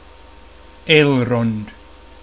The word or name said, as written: Elrond